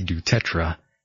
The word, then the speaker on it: du Tertre: Yes, and joining me here live, folks, is Nancy Dutertre, and I sure hope I pronounced your last name correctly.